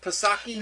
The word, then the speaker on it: Psaki: Is it Psaki? – No, I don't think there's you don't pronounce the P. – The P is silent, just like the P in president is silent.